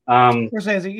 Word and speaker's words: Scorsese: You know, I'm really inspired by, I don't know how you really pronounce the name, Scorsese. – Scorsese, yeah, that works.